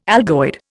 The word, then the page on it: algoid: Indian